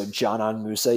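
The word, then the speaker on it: Džanan Musa: My favorite thing about Zanon, and it's actually, you're supposed to say it like a J, so Zanon Musa, even though it starts with DZ.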